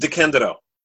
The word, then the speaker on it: DeCandido: No, it's pronounced DeCandido. – DeCandido, thank you.